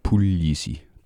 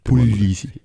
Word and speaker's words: Puglisi: Så man øh, udtaler ikke uh, ged. Det, det er mere som i L-J-I-S-I. Pulisi. – Pulisi.